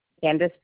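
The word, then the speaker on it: Candice: Candace Brashke. – All right. Is your first name C-A-N-D-A-N-C-E? – No, it's C-A-N-D-I-C-E.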